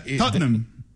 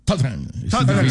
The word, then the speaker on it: Tottenham: Tottenham. Tottenham. Son dos sílabas. – Ajá. Es difícil, ¿no? – Nosotros los británicos. Pero... – ¿Solo 2 sílabas? – Sí, Tottenham.